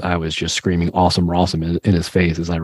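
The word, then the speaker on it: Rossum: Like, that's why you don't kick it to a man whose name rhymes with awesome or some shit like that. So I have a soft spot for Allen Rawsome.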